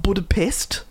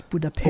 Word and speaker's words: Budapest: We had to look it up because it's not Budapest. – No, no, it's Budapest. – Or Budapest.